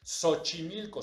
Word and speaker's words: Xochimilco: Si hasta la fecha nosotros vemos el Xochimilco, suena sh, shola, suena sh.